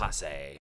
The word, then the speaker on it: Rapace: I already forgot how to say her name. Nomi Rapace. – Rapace.